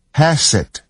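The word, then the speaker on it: Hesed (חסד): 这里有一个关键词可以帮助我们了解整个预言，希伯来文是 CHESED Hesed，这个字第一个音节的发音是是喉音，在英文里面并没有这个字。要知道我们的圣经只是翻译本，并不是原文，所以会有一些限制。在英文里面没有旧约圣经的 Hesed 这个字，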